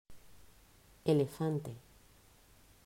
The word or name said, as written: Elefante